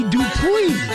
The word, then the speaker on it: Dupree: Actual pronunciation, Robbie Dupree.